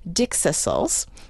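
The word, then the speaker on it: dickcissel: He said every so often they get a year that is exceptional for dixisals.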